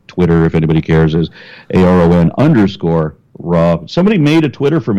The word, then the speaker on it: Aron: And my channel, of course, on YouTube is A-R-O-N-R-A, right?